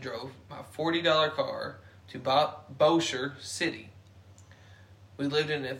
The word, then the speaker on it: Bossier: Did I say it right? Bossier City? – Bossier. – Bossier City, Louisiana.